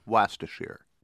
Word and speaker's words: Wastershire: If you guys are right, they should change the expression to take it with a dab of Worcestershire, or as the Brits pronounce it, Wastershire.